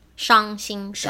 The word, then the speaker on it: 伤心: So sad, S-H-A-N-G. – That's right. – X-I-N. – 没错. – Right? 伤心. – 伤心. – 伤心, okay. 对. Now, trust. X-I-A-N-G. – Very good. – X-I-N.